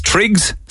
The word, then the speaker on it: Triggs: Is it Trix? Why did I think it was Trigger? Because I'm an idiot, I suppose. I know that many people have dogs. Triggs? Spell it. T-R-I-G-S? T-R-I-G-G-S? All right, okay.